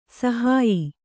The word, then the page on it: ಸಾಹಾಯ್ಯ: sāhāyya